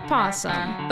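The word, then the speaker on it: opossum: Other is brought to you by the Pink Possum. – But it's spelled O-Possum. – The O is silent.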